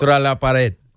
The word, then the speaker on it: Contra la pared: Contra la pared. So it means back against the wall. – Okay. – So Contra la pared.